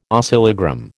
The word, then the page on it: oscillogram: American English